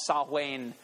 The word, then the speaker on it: Samhain: It's spelled Samhain, but it was actually pronounced Samhain by the Celts.